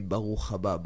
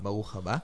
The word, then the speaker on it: Baruch Haba: It's not Baruch. It's no. Baruch Haba. – Baruch Haba.